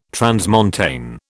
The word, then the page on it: transmontane: British English